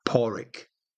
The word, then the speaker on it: Padraig: Paulrick, Paulrick. And that's spelled P-A-D-R-A-I-G. And that's Celtic for Patrick.